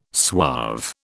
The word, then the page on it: suave: British English